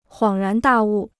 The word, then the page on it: 恍然大悟: huǎng rán dà wù